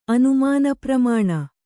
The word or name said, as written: ಅನುಮಾನಪ್ರಮಾಣ